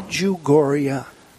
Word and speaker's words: Medjugorje: And I had it took me about 2 years to try to say it right. – Yeah. – Medjugorje, Yugoslavia at the time in 1990.